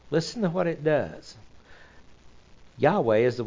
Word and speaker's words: Yahweh: There are none in the Tetragrammaton and Yahweh, and it says, really, if you pronounce it correctly, it goes, Yahweh. The inhale is, Yah. Exhale is, Weh.